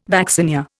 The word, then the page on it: vaccinia: Indian